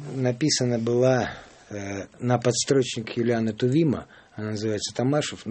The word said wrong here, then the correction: Томашов: но знаменитая Томашов, по-моему, Томашов, так, да. Правильно? – А я вот с ударениями не точен.